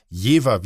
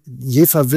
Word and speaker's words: Jever: Also Jever wird mit F sozusagen ausgesprochen. Das liegt daran, das ist der friesische, der niederdeutsche Sprachgebrauch. Das merkt man auch zum Beispiel bei Wilhelmshaven. Mhm. Das schreibt man auch mit V, aber man spricht es eben mit F aus.